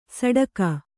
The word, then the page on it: ಸಡಕ: saḍaka